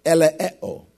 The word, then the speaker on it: eleeo: Now, this word mercy in the Greek is the word eleeo. It's spelled E-L-E-E-O, eleeo.